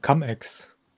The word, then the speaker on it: Cum-Ex: Wird er so ausgesprochen? – Ich glaube, Cum-Ex.